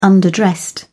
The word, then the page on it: underdressed: British English